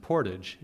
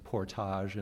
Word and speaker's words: portage: It's a word pronounced portage in English. I suppose it's portage in French.